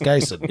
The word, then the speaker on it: Gysin: And I've been saying Gysin wrong. It's Gysin.